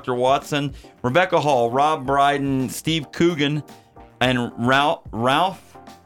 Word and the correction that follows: Ralph: Fiennes. I always say it wrong. – Rafe Fiennes. See, I always do it. I always do it. Rafe Fiennes.